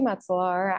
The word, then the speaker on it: Metselaar: So my name is Lindsey Metselaar, as Katie very well pronounced.